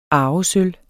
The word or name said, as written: arvesølv